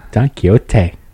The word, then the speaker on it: Don Quixote: But I don't know what you would do with Don Quixote. But it would be nice to have an elderly. – It's a lovely accent you've got there, though. Oh, my. – What? – Don Quixote.